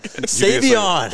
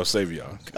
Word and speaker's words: Savion: You said it wrong again. – Savion. – There you go, Savion.